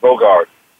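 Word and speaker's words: Bogut: I think his last name is pronounced Bogart.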